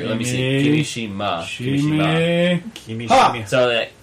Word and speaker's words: Kimishima: It's ki, as in me, not like... – Yeah. Kimishima. – Not like Kimishima or Kimeshima or...